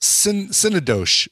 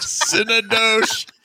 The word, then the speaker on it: Synecdoche: Synecdoche. That's how it's pronounced. – Synecdoche. – Synecdoche.